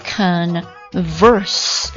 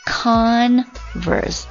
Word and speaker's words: converse: 好，注意一下这个 converse, 它作为动词的时候，它的读音是 converse。但是它作为名词和这个形容词的时候啊，它读作 converse, converse。